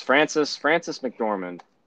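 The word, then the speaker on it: Frances McDormand: Everyone went up there, And Francis McDormand? Is that how you say it? You know who I'm talking about? I have no fucking idea. – Okay. Yes, Francis McDormand, who was the like lead actress in that movie.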